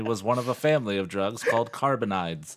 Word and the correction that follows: cannabinoids: I think it's pronounced cannabinoids.